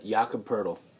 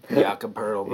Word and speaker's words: Jakob Poeltl: Jakob Poeltl.